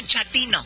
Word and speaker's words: Chatino: ¿Chapino? – No, Chatino. – Es como chato, ¿verdad? Chatino. – Sí, así me era como chato, Chatino.